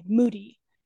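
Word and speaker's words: Mudi: It's pronounced kind of like mood, M-O-O-D, but it's M-U-D-I, Moody.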